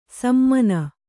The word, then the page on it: ಸಮಾನ: Kannada